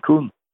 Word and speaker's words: Koen: So, I mean, people would have a hard time hearing the difference between Brian and between Kuhn. – Yep. – It's actually a weird name. It's for American people, but it's spelled K-O-E-N, and in America, I know it's not a great word, you know, but that happens to be his name.